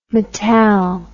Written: metall

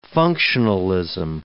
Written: functionalism

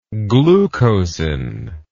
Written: glucosin

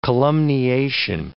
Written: columniation

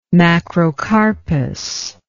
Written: macrocarpous

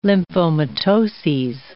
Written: lymphomatoses

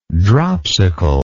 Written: dropsicalness

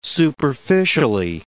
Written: superficially

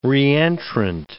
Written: reentrant

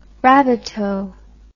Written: rabbitoh

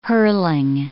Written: hurling

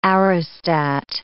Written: aerostat

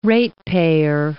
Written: ratepayer

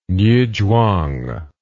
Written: niuzhuang

